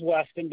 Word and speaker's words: Weston: First name's Weston, W-E-S-T-O-N. – I'm sorry, you said it a little fast. What is it? – W-E-S-T-O-N.